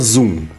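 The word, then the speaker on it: Xoom: O Brendo Marinho falou que é Shum, mas pronuncia Zoom. Isso, tá obrigado. O X é ah, mudo.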